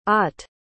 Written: ott

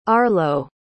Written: arlo

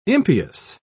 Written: impious

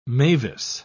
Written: Mavis

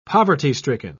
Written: poverty-stricken

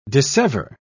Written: dissever

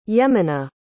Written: yemena

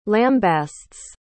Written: lambastes

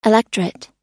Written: electret

